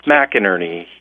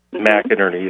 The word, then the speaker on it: McInerney: and how do you pronounce that? – Ralph McInerney. He was McInerney. – McInerney, that's right.